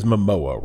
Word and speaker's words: Momoa: His last name is Momoa, right? – Yeah. – Okay. Sounds like I'm trying to, like, mispronounce MMO.